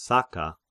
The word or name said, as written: Saka